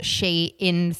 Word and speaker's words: Shein: I did Google the other day and it is called Shein. – Right, okay. – Oh. Yeah, that because it used to be called Shein something.